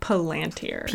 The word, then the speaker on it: Palantir: So since we're talking so much about the Seeing Stones, how do you say that again? – Palantir? – Palantir. – P-A-L-A-N-T-I-R.